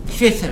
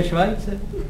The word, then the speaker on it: Schweitzer: Myslíte náhodou Albert Schweitzer? – No já myslím toho, jak v Africe tu nemocnici jak nechal postavit. – No to se vyslovuje Švajce. – Co? – To je Švajce právě.